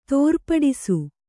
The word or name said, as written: ತೋರ್ಪಡಿಸು